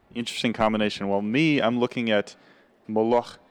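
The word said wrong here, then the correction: molokhia: Say again for me. How do you say it? – Molocheya. – Okay, molocheya.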